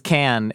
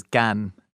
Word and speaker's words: Cannes: The Cannes, what about people, I had an argument where people are like, they're like, it's can, and some people are like, no, it's con. And I'm like, no, the French vowel sounds are just different. There's no proper, the only way to pronounce it is in French, which is Cannes.